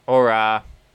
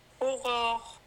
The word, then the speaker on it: Aurora: Aurora. Aurora. – You got to get it back in your throat. – Yeah. – Yeah. – But it's very French.